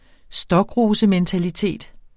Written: stokrosementalitet

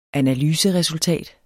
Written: analyseresultat

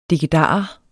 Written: dikkedarer

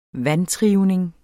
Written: vantrivning

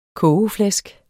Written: kogeflæsk

